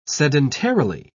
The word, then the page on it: sedentarily: British English